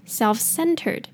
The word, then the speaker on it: self-centered: self-centered self 的 E 呢念短的 E 的音那 L 要卷舌然后最后那个 F 的音发出来 self self再来后面那个 center 大家应该会念那最重要的是后面那个 ed 记得要点出来 self-centered self-centered self-centered